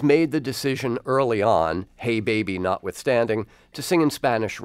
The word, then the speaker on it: Jei Beibi: It's J-E-I, that's the first word, and then the second word is B-E-I-B-I. Okay, so J-E-I-B-E-I-B-I. How do you say this album title? – Hey, baby. – So it's a little bilingual joke. It's a joke for the English-speaking listeners.